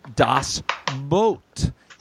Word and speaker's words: Das Boot: It's pronounced as boat. Sorry, had to tell, LOL. – Oh. – It's Das in German. It's pronounced Das Boot, even though spelled double O. Okay, but here's where I want to say both thank you, Linda. Also, not so fast. Far be it from me to defend Dean Haglund in a pronunciation, but yes, the German pronunciation is closer to boat.